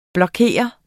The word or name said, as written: blokere